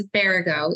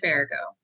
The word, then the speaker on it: Baragau: But yes, it's Baragau, Ioana Baragau.